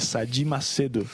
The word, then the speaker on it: Sayid Macedo: ¿Cómo se pronuncia en tu idioma? Y luego nosotros, ¿cómo lo hemos traducido al español? – Claro, pastor. Mi nombre es Sayid Macedo.